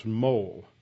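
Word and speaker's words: Moule: I've just mispronounced his name because I always have. Discovered this morning it's actually pronounced Mole.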